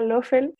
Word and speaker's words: Loffel: Pueden ver mis videos en mi canal de YouTube, Clara Loffel, se escribe L-O-F-F-E-L, e Clara Loffel en YouTube.